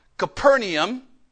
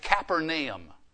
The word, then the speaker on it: Capernaum: And after this, he went down to Capernaum, or I discovered when we went to Israel talking to a national speaker, they actually pronounce it Capernaum.